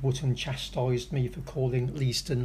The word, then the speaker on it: Leiston: Well, that well-known connoisseur of the English language, Andy Waller, has been in touch to inform me that neither, is correct. He sent me proof that it's pronounced Leiston.